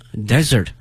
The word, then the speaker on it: dessert: 但是呢这两个词的它的拼写呢一模一样的，但是呢发音不一样，名词 desert 沙漠，动词呢 desert 抛弃。那么还有一个词呢 d-e-s-s-e-r-t 多了一个 s, 它的发音是 desert, 它是指呢我们去点快餐的时候呢会有一些小的甜品，比如说像奶昔啊冰激凌啊等等，那么它通称为 dessert 啊，甜品。